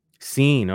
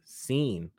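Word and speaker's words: Cine: It's C-I-N-E. Sine. Okay. Thank you, Pro Football Reference. Pronounced Sine.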